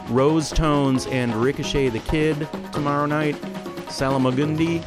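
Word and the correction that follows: Salmagundi: I'm sorry, I added a syllable. Salamagundi is at Zalgaz Grotto tomorrow, starting at 8.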